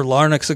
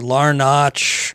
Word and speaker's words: Larnach: And, you know, Trevor Larnock's a guy that I've probably called Trevor Larnach, Trevor Larnick, um, all kinds of weird things.